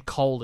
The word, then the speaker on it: Kohl: By the way, the name Cole is spelled K-O-H-L.